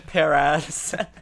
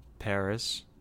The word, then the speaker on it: Paras: Paras. – I've been working really hard not to say that. – How do you pronounce it? – Paris. – There you go. Good job.